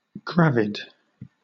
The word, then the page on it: gravid: British English